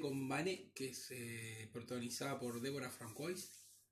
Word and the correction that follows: François: ¿no? – François. – François perdón mi, pronunciación.